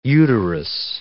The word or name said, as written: uterus